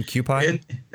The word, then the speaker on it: Kewpie: I don't know if it's called Kewpie, K-E-W-P-I-E. Yeah. I don't know if that's... Is that the pronunciation, Kewpie? – Kewpie, K-E-W-P-I-E, pronounced okay. Kewpie.